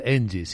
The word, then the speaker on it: ENGIE: Sí, en o ENGIE, como se pronuncia. Sí, en, en castellano literal, ENGIE, sí.